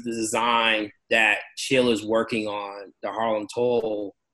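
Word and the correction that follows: Toile: It's pronounced Twal. Sheila will kill me. Twal. – Okay, thank you. Twal. Thank you.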